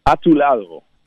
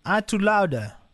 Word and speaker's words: A tu lado: Hoe spreek je het uit? – A tu lado. – A tu lado. – A tu lado. – Lado. Gewoon zoals het eigenlijk geschreven staat.